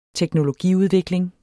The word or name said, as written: teknologiudvikling